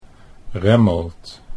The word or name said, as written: Remmelt